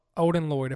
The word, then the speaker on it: Odin Lloyd: And, well, he was convicted of the first murder of Odin Lloyd, if I'm saying it right.